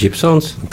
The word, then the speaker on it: Gibson: Tā, tā bija Gibsons. – Tā tad Gibsons gan Gibsons šeit rakstīts izrunā Gibson.